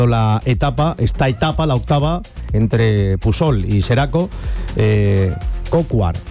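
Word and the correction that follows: Coquard: seguramente no se dice así, pido disculpas, el ciclista francés. – Brian Cocar, que es precisamente el que está hablando, que imagino que no sé si se le entiende o no.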